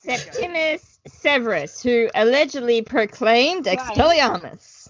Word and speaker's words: Septimius Severus: Septimius Severus.